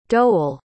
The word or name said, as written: doughal